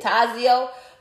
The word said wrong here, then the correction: Tazo: Tazo. It's that T-A-Z-O.